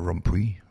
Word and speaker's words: Rompuy: And remember Mr. Von Rompuy, or Rompuy, how he pronounces it,